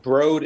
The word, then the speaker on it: Broad: Moving on to testing, good news, the Broad Institute, spelled like the word broad, B-R-O-A-D,